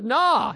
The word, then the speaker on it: gnaw: Same with gnaw. Gnaw and gnat.